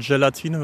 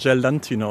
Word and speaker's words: Gelatine: Die Zutat Gelatine wird ganz oft Gelantine ausgesprochen.